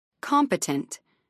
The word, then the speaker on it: competent: Competent. 3 syllables, main stress on the first syllable.